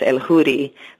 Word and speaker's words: Eljuri: The J is like an H in English, so it's El Houri, but it's spelled E-L-J-U-R-I.